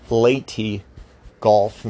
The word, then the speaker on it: Leyte Gulf: Now that was in the Philippines, over the Philippine Sea, and that combined with the Battle of Leyte Gulf, just to make sure I said it correctly, Leyte Gulf, not Leyte Gulf, and also the complete and utter destruction of the super carriers, like the Yamato, complete obliteration of the Japanese Navy in Midway.